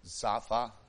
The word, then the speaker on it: Zophim: Now that word Zophim is actually pronounced Zapha in Hebrew, but we're not speaking Hebrew, so.